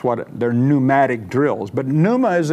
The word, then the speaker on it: pneuma: Now, even as we go through this, remember the Greek word for Spirit, in case you want to know, is pneuma. It's spelled P-N-E-U-M-A. It's like you hear about pneumatic drills or pneumatic tires or what have you like that.